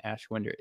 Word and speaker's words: Ashwinder: And I don't even know what an Ashwinder is. – Ashwinder, you mean? – Ashwinder is... Is that how you pronounce it? – Yeah, it's a magical creature that you know, it whines through ash.